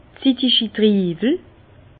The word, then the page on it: zitigi Triiwel: Bas-Rhin; Herrlisheim